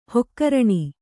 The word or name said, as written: ಹಗರಣೆ